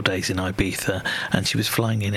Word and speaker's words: Ibiza: She's had, either three, might have even been 4 days over in Ibiza, which I'm going to pronounce it properly as Ibiza, unlike the Wenger boys coming up.